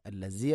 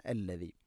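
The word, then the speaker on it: അല്ലദീ (الذي): ഹുവല്ലാഹു അല്ലദീ, ഹുവല്ലാഹു അല്ലദീ, അല്ലദീ, ദാലിൻ്റെ ഉച്ചാരണം ശ്രദ്ധിക്കുക. അല്ലസീ അല്ല, അല്ലദീ.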